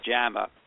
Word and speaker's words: JAMA: A study in JAMA, remember, the Journal of the American Medical Association, pronounced JAMA, really a prestigious journal, it ranks high in points for the authors.